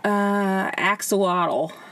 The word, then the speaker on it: axolotl: Axolotl.